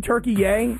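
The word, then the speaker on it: Türkiye: Listen, I liked saying Turkey Yay because I found this interesting that there was this new pronunciation of the country Turkey that I had no idea about.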